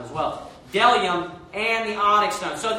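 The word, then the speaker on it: bdellium: Then it says, there is delium and onyx stone. So that B is silent.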